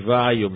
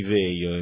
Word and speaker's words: Vaio: D'altra parte io non lo sapevo, ho scoperto solo dopo che non si dice Sony Vaio, ma Sony Veio, e me ne scuso con gli ascoltatori.